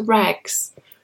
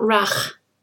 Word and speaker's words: rag: For example, if we've got an old t-shirt and we rip it up into rags to clean the window with, for example. So the pronunciation is rag, not rach. Rag.